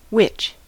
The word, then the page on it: witch: American English